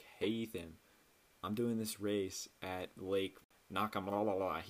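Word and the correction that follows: Nockamixon: He didn't know how to say it. I was like, "Oh, Lake Nakamixon."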